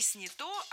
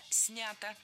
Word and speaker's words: снято: Я точно не соглашусь, потому что промо было не снято, а снято. Ударение в этом кратком причастии оставляем на корне, как и в большинстве глагольных форм. Снять, сняли, сняты, снято.